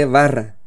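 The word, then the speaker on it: bara: El verbo que traducimos como creó en el hebreo se lee barra, que se escribe vara.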